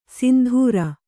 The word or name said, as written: ಸಿಂಧುರ